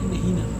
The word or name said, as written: nehina